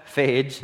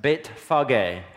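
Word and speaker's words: Bethphage: As they approached Jerusalem and they came to uh, Phage is how we butcher it in English. But Beit Fage, house of the early fig.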